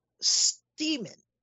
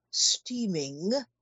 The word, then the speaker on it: Steamin': And this little concoction is called Steamin', S-T-E-A-M-I-N, apostrophe. It's not meant to be steaming. It's meant to be pronounced steamin'.